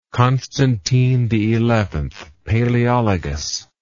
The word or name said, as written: Constantine XI Palaeologus